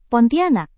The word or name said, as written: Pontianak